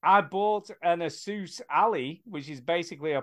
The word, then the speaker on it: Asus: An Asus Ally.